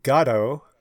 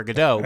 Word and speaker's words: Godot: But everyone gets it wrong in America, and people say it right in Ireland. The reason I am still so stubborn about saying Waiting for Godot is because Beckett himself has said, no, Godot is not God.